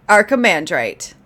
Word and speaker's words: Archimandrite: Archimandrite. – Archimandrite. That's how you say it.